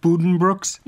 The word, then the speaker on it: Buddenbrooks: People who've read Colm Tobin's The Magician will have a passing acquaintance with, is it pronounced Buddenbrooks? – Yes.